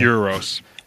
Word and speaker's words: Euros: and it turns out that Eros, did I say it right that time? – Euros. – Sister. – Euros. – Like Europe. – Okay, Europe, I got it.